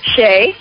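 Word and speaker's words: Shea: Shay.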